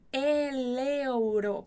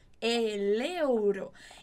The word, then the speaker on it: el euro: Atenção nessa pronúncia, el euro, porque a gente junta o L com a vogal que começa outra palavrinha, né? Euro, então, el euro.